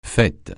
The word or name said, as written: fête